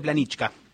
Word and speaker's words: Planička: Se escribe Planica, suele pronunciarse Planička.